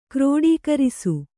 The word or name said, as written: ಕ್ರೋಡೀಕರಿಸು